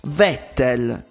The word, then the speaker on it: Vettel: Vettel si scrive con la V, ma si legge con la F.